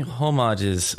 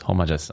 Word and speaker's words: homage: What about using homages from movies or other games? Homages. Oh, I always mess up on the word. – Homage. – Homage. – I think the H is silent.